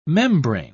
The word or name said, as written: membrane